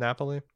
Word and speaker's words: Napoli: Is that how you pronounce it? Napoli? – Mm-hmm.